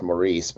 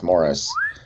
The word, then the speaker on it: Maurice: Maurice, um, spelled like it should be pronounced Maurice, but it, I guess it's Morris.